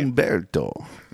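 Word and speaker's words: Humberto: Umberto. – According to our friends at Weather Nation. – Okay. – The H is silent.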